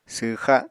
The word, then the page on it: swxa: apatani